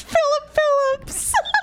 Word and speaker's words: Philip Phillips: Gospel singer Anthony Evans and American Idol Season 11 winner Philippe. – No, it's Philip Phillips. Philippe. Philip.